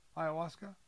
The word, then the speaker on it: ayahuasca: We think that's how we say it, ayahuasca? – Yeah, that's how you say it.